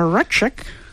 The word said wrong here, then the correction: Rychik: Did I say it right, Bechdel? – We'll hear. – Yes, I think it's Rychik. – Rychik. Something like that.